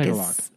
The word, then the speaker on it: Tagalog: Tagalog? Is that the... – Tagalog, I think. – Tagalog. – It's spelled T-A-G-A-L-O-G, I think. – I think so.